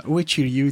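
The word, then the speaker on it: Uechi-ryū: You uttered it just the right way. Uichi Ryu, it's called Uichi Ryu, and Zdenko Domanchik.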